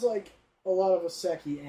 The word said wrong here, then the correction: Isekai: or Isekai animes, I can't remember how you say it at the moment, or... – Isekai? – Yeah. I thought so. I knew that first saying didn't sound right.